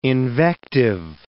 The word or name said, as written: invective